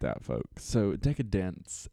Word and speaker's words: Decadence: Decadence. I said it right this time. For those of you who don't know, it's definitely not Decadance. I was saying that for like the last year.